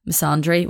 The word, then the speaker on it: misandry: Misandrist, by the way, is the opposite of, well, misandry is this, is that how you say it? Misandry? Wait, I'm going to look it up and click the thing for it to speak to me. Sandry. Misandry, yeah. Sandry. Why is he not pronouncing the M? Misandry is the opposite of misogyny.